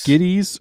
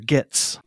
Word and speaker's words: Gittes: And I have a point here, is that, so it's Gittes, it's spelled G-I-T-T-E-S, but I feel like it's pronounced six or seven different ways. – It really is. Thank you. – In the movie. – Everyone pronounces it differently. – Two ways. – Oh, so there's only two? – Mm-hmm. – I feel like it was like Gittes and... – Giddies, or like a Giddies? – Yeah, there's like a Giddies.